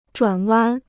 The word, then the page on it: 转弯: zhuǎn wān